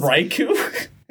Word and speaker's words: Raikou: Raikou? – Yeah, that's what I couldn't pronounce.